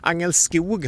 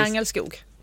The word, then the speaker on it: Angelskog: Angelskog har jag lärt mig här att man ska uttala det. Jag vill gärna trycka på början där, men man lär sig. – Ja, det är ni Karlskroniter som håller på med det. Vi i Ronnebybor säger Angelskog.